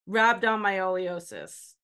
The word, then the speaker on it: Rhabdomyolysis: Rhabdomyolysis.